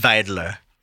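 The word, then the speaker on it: Wilders: Gert Wilder, I believe his name is. – It's pronounced Weidler.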